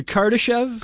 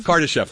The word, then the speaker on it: Kardashev: And we're trying to understand, we want to sort of, you know, go beyond the sort of Karadash, if I can pronounce it right, the Karadashov. – Hold on, I've got the... Yeah, the Kardashev? – Kardashev, thank you.